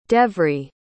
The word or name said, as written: devri